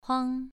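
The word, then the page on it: huang: tone 2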